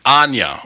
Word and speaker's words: Anya: Ayanna Hartpence. Is that correct? Did I say your name right there? – That's pretty close. It's Anya. – Anya. Okay, Anya. That's a beautiful name. How do you spell that? – That's A-N-Y-A.